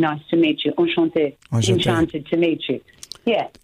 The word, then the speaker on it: enchanté: How we pronounce enchanté, is that it? – It's enchanté. – It is enchanté, exactly.